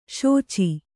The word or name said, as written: ಶುಚಿ